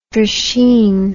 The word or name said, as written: drisheen